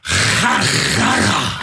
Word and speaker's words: Herrera: Åssen uttaler du Herrera? Herrera! Riktig.